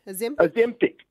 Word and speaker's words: Ozempic: And, of course, she did late last year admit that she was taking this drug, Ozempic. Is that how you say it? Ozempic? – Ozempic? – Ozempic. Yeah. Ozempic. That's it.